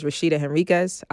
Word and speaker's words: Rashedah Henriques: So everybody who doesn't know me, all right, my name is Rashedah Henriques, also known as Shedah 3 on IG. And yes, I say it like that every single time.